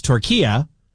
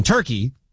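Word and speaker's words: Türkiye: We just go with it because, again, much like last year or last hour, I learned that it's not Turkey, it's Turkiya, which is the correct way to pronounce what we have been calling Turkey, the country, for a number of years.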